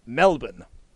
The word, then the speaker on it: Melbourne: I'm a little behind on the rambles about how you pronounce Melbourne. Luke was 100% right. It is Melbourne.